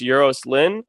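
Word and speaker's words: Euros Lyn: And then the director, I think his name is pronounced Euros Lynn.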